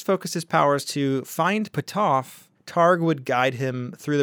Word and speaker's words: Puthoff: So Pat Price would then use his ESP to try and locate Puthoff. And can I see how it's spelled? I'm like really curious. – It's P-U-T-H-O-F-F. Puthoff.